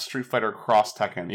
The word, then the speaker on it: Street Fighter X Tekken: Like Street Fighter, like, the fighting game that's a crossover between Street Fighter and Tekken is pronounced Street Fighter Cross Tekken, even though it's spelled, like, Street Fighter X Tekken.